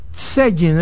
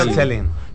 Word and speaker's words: selling: Él es especialista, como dije, en marketing, también en social selling, es que se pronuncia. – Social selling. – Selling.